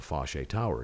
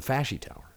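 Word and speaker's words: Foshay Tower: And he laughed and he said, it's Fashy. It's the Fashy Tower.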